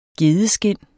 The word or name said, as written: gedeskind